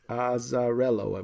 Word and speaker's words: Azzarello: He wrote it with Juergens and Zerolo. – Yep. – Is that how you say it? Okay, this is the Italian, Fred. You got to help me. Azzarello, I believe.